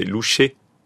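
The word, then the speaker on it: louchai: Et en fait, la prononciation, c'est louchais. L'équivalent d'un E accent aigu.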